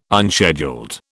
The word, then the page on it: unscheduled: British English